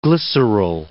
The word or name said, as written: glyceryl